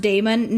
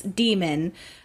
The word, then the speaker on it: daemon: Somebody says that's not pronounced daemon. No, it's demon.